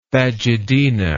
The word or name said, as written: phagedena